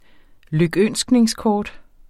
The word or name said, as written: lykønskningskort